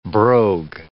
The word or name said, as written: brogue